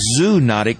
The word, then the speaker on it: zoonotic: Now, that word looks like zoonotic, but it's actually pronounced zoonotic diseases. Rabies is the best known and perhaps the oldest of all zoonotic diseases.